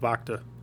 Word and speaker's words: Bacta: The Bantha tank. – That thing. – Of Bacta.